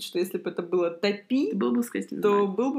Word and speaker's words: Топи: Топи. – Я увидев это в названии, решила, что на ударение ставится на вторую буковку.